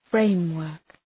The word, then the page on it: framework: {‘freımwɜ:rk}